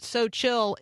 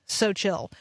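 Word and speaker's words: Seaux Chill: You just need to know that So Chill is spelled as if the word so is French. S-E-A-U-X, So Chill.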